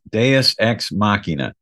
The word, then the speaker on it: Deus Ex Machina: Is this Latin? And I said, oh, Deus Ex Machina. Yes, that's Deus Ex Machina.